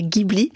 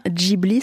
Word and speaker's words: Ghibli: Les studios Ghibli, alors peut-être d'ailleurs, précisons que la prononciation, on entend souvent dire Ghibli. Or, la prononciation exacte, c'est bien Ghibli.